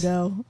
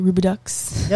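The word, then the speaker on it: Rubidoux: It's Rubido, but some people say Rubidux. – Yeah, because it has an X at the end. – No, the X is silent.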